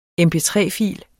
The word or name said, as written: MP3-fil